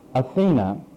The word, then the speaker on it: Athene: So we look at the word Athens, and it comes from the word Athene. In some places it's pronounced Athena. And Athene, or Athene, means the goddess of wisdom who founded the city of Athens.